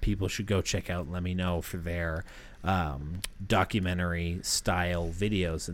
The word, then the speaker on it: LEMMINO: It's spelled LEMMINO, like Let Me Know. – Gotcha. – But it's supposed to be, you know, Let Me Know is what it is.